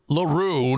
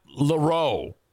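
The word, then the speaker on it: L'Heureux: It's definitely LaRue, not LaRoe, because someone asked the player, me.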